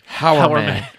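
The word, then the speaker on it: Hourman: Oh, hour, H-O-U-R. Yeah. Not hour as in O-U-R. – No. Hour. I really can't pronounce the H. I can't be like, Hour Man.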